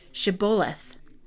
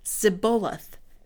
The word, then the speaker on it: Shibboleth: If the man said, No, I'm not, they would tell him to say, Shibboleth, if he was from Ephraim, he would say Zibboleth, because people from Ephraim could not pronounce the word correctly.